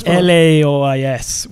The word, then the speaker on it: Laois: It's Laois, which is very close to Laos and Laois, or Lao, depending on how you try to pronounce it.